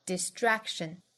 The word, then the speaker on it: distraction: Distraction 这个词重读在第二个音节上 da-da-da, distraction. 这个音后面的轻辅音呢可以做不送气处理所以后面这个 tr 我们听到更多应该是 dr 的发音 distraction 那 k 的发音后面遇到辅音前面的这个 k 做不完全爆破所以不是 traction 而是 traction 非常轻微的一个爆破